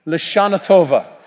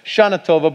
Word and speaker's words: L'Shana Tova: L'Shana Tova. Now, you don't always hear the L when they say Shana Tova to you. It's usually just Shana Tova, but let's try it with the L one more time.